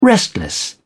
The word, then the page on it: restless: British English